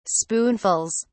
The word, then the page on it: spoonfuls: British English